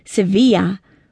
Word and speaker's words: Sevilla: Her name was pronounced in the manner of the beautiful Andalusian city in Spain, as Sevilla, or as Sevilla Ray was ready to say, "It is like severe, but with a long E in the middle and an A at the end, like you'd say when you understood something."